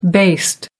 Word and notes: based: American English

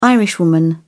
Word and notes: irishwoman: British English

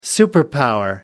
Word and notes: superpower: American English

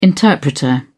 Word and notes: interpreter: British English

